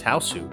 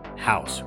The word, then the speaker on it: House: One of the most bonkers films I've ever seen and an icon of the horror film genre, often mispronounced as Hausu, we're covering the Japanese film House, released in 1977.